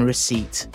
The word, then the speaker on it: receipt: And by far the most irritating of all silent letters, you will not change my mind, is the P in receipt.